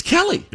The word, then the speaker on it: Kelly: and is it Keely Teller, Miles Teller's wife, Kelly. – Good friend? – Yes. – It's just pronounced Kelly. – Is that right? – Yeah, Kelly Teller.